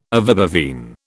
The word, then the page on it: ovibovine: British English